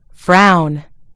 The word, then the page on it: frown: British English